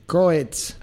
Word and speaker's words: Coez: Coetz. – Ok. – Coetz, que se escribe C-O-E-Z. – Z. – Coetz. – Exactamente. – En italiano, Coetz.